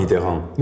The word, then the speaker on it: Mitterrand: ¿Cómo se dice François Mitterrand? Mitterrand. Mitterrand, no, está tan mal, la verdad.